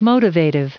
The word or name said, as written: motivative